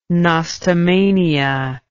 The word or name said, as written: nostomania